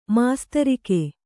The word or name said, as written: ಮಾಸ್ತರಿಕೆ